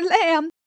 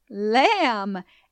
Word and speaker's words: lamb: Silas, the silent letter lamb. The letter B is silent in my name, lamb, and in the word doubt.